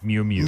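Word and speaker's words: Miu Miu: The fashion brand, I believe it's pronounced Moo Moo. – I think it's Mew Mew.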